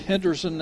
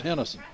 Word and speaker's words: Henderson: Now, it's pronounced Henderson now. When I was growing up, it was Henderson. – You can say it either way, and it's considered legitimate.